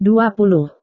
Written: dua puluh

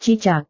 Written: cicak

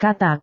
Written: katak

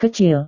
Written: kecil

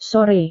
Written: sore

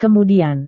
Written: kemudian

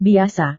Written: biasa

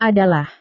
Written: adalah

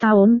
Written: tahun

